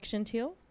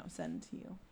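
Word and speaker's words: Sendhil: Like Chantil? – No, Sentil.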